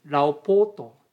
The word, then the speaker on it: 老婆: また老婆と書いてですね、ラオポーと読むんですけども、これは年老いた女性という意味ではなくて、妻という意味がありまして、親しみを込めて使われている言葉であります。